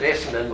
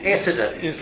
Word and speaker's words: Essendon: The only problem with that is he pronounced it Essendon with the N. The correct pronunciation avoids the N. Essendon. Get it, Essendon.